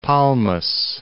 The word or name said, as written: Palmas, Cape